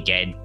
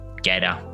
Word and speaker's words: Gade: and Peter Gade, or Gader, depending on where you're from, how you say it, in 2009 at the Singapore Open.